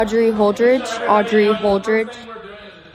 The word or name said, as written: Audrey Holdridge